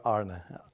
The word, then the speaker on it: Arne: I listened to that name, but we in Holland would call me Arne, which is not an easy one to pronounce. So I listened to Arne as well, but I would prefer Arne.